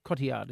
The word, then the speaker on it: Cotillard: and Marion Cotillard? Cotillard is how you pronounce it.